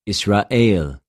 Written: Ἰσραηλ

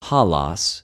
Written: ὁλος

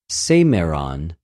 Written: σημερον

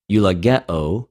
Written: εὐλογεω

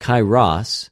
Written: καιρος

